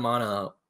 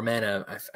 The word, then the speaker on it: Mana: Secret of Mana or Mana, people pronounce it both.